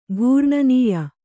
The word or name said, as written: ಘೂರ್ಣನೀಯ